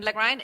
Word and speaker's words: Lagrein: I mean, today we talk Lagrein, and Lagrein is the name, is a German name, right? It's a German name. It's not the way it's spelled.